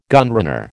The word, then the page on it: gunrunner: American English